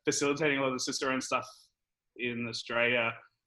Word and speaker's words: Cicerone: How you pronounce it? – Cicerone.